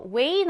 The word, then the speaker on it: weigh: 이어서 way 나왔습니다. 스펠링을 보시면 weigh 인데 읽을 때는